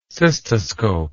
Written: cystoscopist

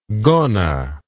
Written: gonna

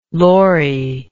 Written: lorre

